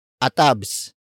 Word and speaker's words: atabs: The next slang word is atabs. Atabs. Atabs. Atabs is spelled as A-T-A-B-S.